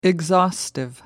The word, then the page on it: exhaustive: American English